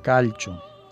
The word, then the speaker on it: calcio: Esto se escribe calcio igual que el calcio, que el elemento. Y bueno, siendo italiano, yo creo que se pronuncia calcio.